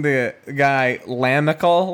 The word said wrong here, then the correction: Lamical: last year, but it was actually Lamichael. – Oh, that's right. That's right. – Because it's spelled L-A-M-I-C-A-L.